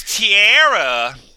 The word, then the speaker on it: Tierrah: My name's Tierra. Oh. That's spelled T-I-E-R-R-A-H.